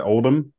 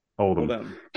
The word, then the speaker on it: Oldham: Is it Oldham? Is that how you say your last name? – Yeah. – Okay, yes, cool. – It's spelled Oldham, but we don't usually say the H. – Oldham.